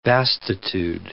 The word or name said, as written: vastitude